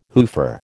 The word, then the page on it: hoofer: American English